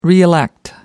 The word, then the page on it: reelect: British English